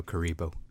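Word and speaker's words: Kuriboh: It was like Kuriba. I'm like, no, Karibo.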